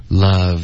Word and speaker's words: love: In love，这个 love 的这个呜的音是两个嘴唇的发音，你要用下面的嘴唇碰到上齿，love， love with.